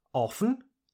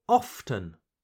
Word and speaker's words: often: You can pronounce it often or often. Often or often. So you can choose to pronounce the letter T or not.